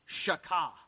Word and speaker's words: shachah: So if you want to write the word worship down, the Hebrew word, because I know you care about this, is shaka. It's spelled S-H-A-C-H-A-H, but it's pronounced shaka, okay?